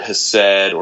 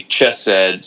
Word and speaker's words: hesed: It really, theologically, is pretty foundational to this record because the Old Testament uses a Hebrew word, hesed, or some, you can spell it H-E-S-E-D, or it can be chesed, C-H-E-S-E-D, but it's like an English transliteration of a Hebrew word that means covenant love.